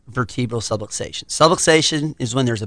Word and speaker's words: subluxation: if medically necessary to correct a subluxation. And I may not pronounce that right, but it's subluxation. – Subluxation. – So there we go. There is the correct pronunciation.